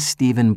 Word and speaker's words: Steven: Hello, I'm Stephen Black. – Hello, Stephen. How do you spell your first name? – S-T-E-V-E-N.